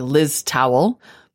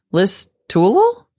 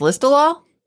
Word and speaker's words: Listowel: And he writes, I wanted to correct the pronunciation of the town, which sure looks like Liz Towel, but is pronounced Listowel. Even though it has a W, it's pronounced Listowel.